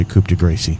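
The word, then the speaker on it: coup de grâce: If anyone would like to give him the old coup de grace right now, they can do it. But that is the proper French pronunciation. – Coup de grace. – Yes. – Yes, your French is very good.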